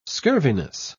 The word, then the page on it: scurviness: British English